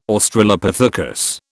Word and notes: Australopithecus: British English

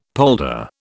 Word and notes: polder: British English

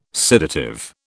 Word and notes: sedative: British English

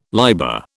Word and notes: liber: British English

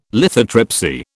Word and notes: lithotripsy: British English

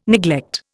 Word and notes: neglect: Indian

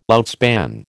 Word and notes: outspan: American English

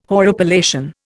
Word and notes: horripilation: Indian

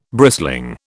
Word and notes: brisling: British English

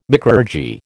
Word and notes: micrurgy: American English

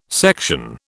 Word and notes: section: British English

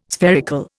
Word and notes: spherical: Indian

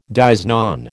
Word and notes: dies non: American English

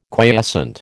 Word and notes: quiescent: American English

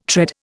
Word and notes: tread: Indian

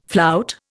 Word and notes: flout: Indian

